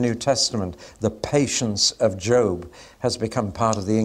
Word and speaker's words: Job: Well, now we come to the book of Job, or should I say Yov, because in Hebrew J is pronounced like a Y and B like a V. But we'll be kind to you because we're English and we'll talk about Job.